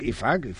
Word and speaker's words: Ifac: Ifac.